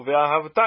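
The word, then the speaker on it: Ve'ahavta (וְאָהַבְתָּ): A sílaba tônica, no caso, é o tav, é o final. Ve'ahavta é a oxítona.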